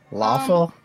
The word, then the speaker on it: lawful: Lawful? You're gonna say glob is lawful? – Lawful. – Well, it rhymes with awful, so.